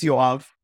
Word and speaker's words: Yoav: My name is Yoav Turel.